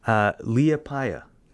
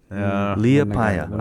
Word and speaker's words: Liepāja: Liepāja. Liepāja. I had to look up how to pronounce that.